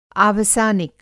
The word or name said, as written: आवसानिक